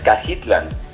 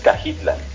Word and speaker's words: Cajitlán: Sí, como es Tamalan, como es Tonayan, todos son nombres náhuatl que requieren esa pronunciación, okay. – Que es una pronunciación sin el acento. – Ok. – Sí, entonces Cajitlán.